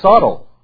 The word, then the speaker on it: subtle: There's a I call it subtle, and I get in trouble because the B is silent. It's what? It's subtle.